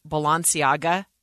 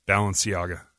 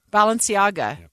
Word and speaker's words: Balenciaga: But unless you are into or can afford high-end luxury fashion, you've probably, are like me, and never heard of Balenciaga. It's a brand. Did I say that right? I don't even know. – No. – How is it said? – Balenciaga. – Balenciaga. Yep.